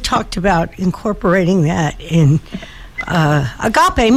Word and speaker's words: Agape: A-G-A-P-E. – And it's Agape. – Agape, yes.